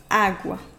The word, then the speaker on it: agua: En espagnol, l'accent tonique tombe ici sur le premier A de ce mot. Autrement dit, la syllabe sur laquelle on met l'accent à l'oral est le premier A, agua. Phonétiquement, cela fait mal aux oreilles de dire la agua, car il y aurait deux A accentués à l'oral qui s'enchaîneraient.